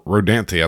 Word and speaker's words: Rodanthe: At that point, it was moved to or Rodanthe, I think is actually how you pronounce it.